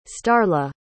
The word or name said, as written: starla